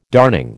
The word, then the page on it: darning: American English